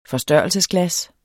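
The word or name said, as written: FORSTØRRELSESGLAS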